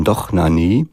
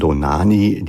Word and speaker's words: Dohnányi: Und zwar einmal dirigiertes von Dohnányi oder von Dohnányi, die es mir deutsch ausgesprochen haben wollen, und den Cleveland Orchester.